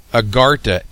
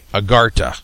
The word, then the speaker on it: Agharta: Now, let me spell that word for you. Agartha, A-G-H-A-R-T-A, Agartha.